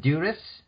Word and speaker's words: Duris: Is it Duris? – Yes, completely okay.